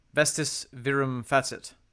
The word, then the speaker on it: vestis virum facit: Vestis virum facit. – That's cool. For those of you who want the actual pronunciation, vestis virum facit.